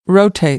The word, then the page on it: rotate: American English; oʊ